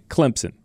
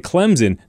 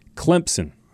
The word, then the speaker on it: Clemson: remember there's a P in Clemson. You don't see it. You don't spell it that way, but you'll get it right if you just spell it C-L-E-M-P-S-I-N, Clemson. It's correct. That is the correct way to pronounce it. A lot of people want to put a Z in there. They want to say Clemson. No, not like crimson.